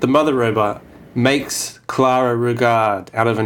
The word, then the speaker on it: Rugaard: He's played by Carla Rugaard. Clara Rugaard. That's what I said. There's two A's, so you have to really lean on the second syllable.